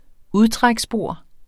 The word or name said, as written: udtræksbord